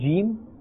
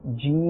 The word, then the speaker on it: jeem: Instead of just saying jeem, alright? Instead of saying jeem, we will say jeem.